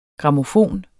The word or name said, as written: grammofon